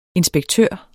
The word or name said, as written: inspektør